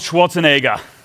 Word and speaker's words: Schwarzenegger: And so Jingle All the Way, it starred the one and only Arnold Schwarzenegger. Sorry, I mispronounced that. Arnold Schwarzenegger.